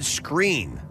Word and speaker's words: Skrein: In this clip, Ed Skrein, is that how you say his name? S-K-K-R-E-I-N? – Yeah.